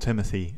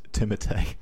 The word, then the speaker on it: Timothée: Interesting fact, though, did you know that it's not Timothy Chalamet, it's Timothée. – Timothée. – It's French Canadian, so you pronounce it Timothée.